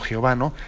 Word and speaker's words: Yahvé: que pronunciamos como Yahvé o como Jehová, según las distintas tradiciones.